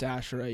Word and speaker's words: Asherah: Is it Asherah pole? – I think it's Asherah, yeah.